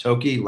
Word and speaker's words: Thokey: Tokey, last name is spelled T-H-O-K-E-Y.